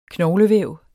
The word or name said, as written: knoglevæv